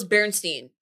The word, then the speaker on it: Berenstain: We were like avid like Bear and Seen Bears watchers and it always used to be spelled like S-T-E-I-N. That's how it's spelled. But it's actually the Berenstain Bears. – No, it's not. – Yeah. – The Berenstain? – Yeah. That's how it's... And that, we always know it's Berenstain. That's how it's pronounced.